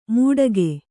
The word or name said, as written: ಮುಟಗೆ